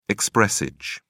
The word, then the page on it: expressage: British English